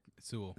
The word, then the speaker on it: Sewell: And this guy, Sewell, am I saying that right, Sewell? – Sewell.